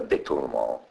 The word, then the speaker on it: détournement: Détournement è una parola francese che, come vedi, viene lasciata détournement, anche perché la prima E è accentata grave, détournement, detto che si pronuncia détournement, sulla prima E accentata perché loro mettono sì, così bravo.